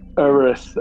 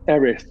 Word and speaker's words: Erith: So I noticed on the front of the script, we've got notes saying that at times it should be pronounced Erith and then Erith.